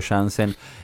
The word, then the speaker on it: Janssen: ¿Por qué laburar con Diego Jansen? Yo le voy a decir Jansen porque me dijiste que él se dice a sí mismo Jansen.